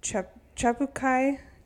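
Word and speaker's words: Tjapukai: And the clue says to make your way to Chabukai Aboriginal Park.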